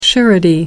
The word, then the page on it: surety: American English